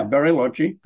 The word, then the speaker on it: Bariloche: Do you think, so if we go to the town of, is it Bariloche? Is that how you pronounce it? – Yeah, Bariloche.